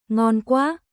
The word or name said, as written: Ngon quá